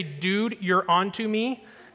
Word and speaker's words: Deuteronomy: And by the way, if you ever need to know how to pronounce Deuteronomy, just say, dude, you're onto me.